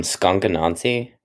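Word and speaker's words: Skunk Anansie: I love Skunk Anansi.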